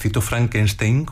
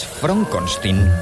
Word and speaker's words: Frankenstein: Soy yo, pero es Frankenstein. – ¿Me está tomando el pelo? – No, se pronuncia Frankenstein.